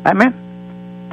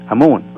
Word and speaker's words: Amen: Amen. They pronounce it Amen, which is actually the correct pronunciation, which was the name of God in ancient Egypt. Amun, or Amen.